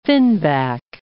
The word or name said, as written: finback